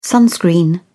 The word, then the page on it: sunscreen: British English